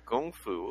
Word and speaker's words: Gung Fu: Yeah, it's actually pronounced Gung Fu, which I learned. – What's the difference? Is like Gung Fu, because Kung Fu is Chinese. – It's just how they romanized it, I think.